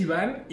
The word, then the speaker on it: Iván: Mi nombre es Iván y es eh, de letra I, V, A, acento N. Pero a todos se les olvida el acento y me dicen gordo, mórbido, obeso.